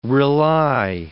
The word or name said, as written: rely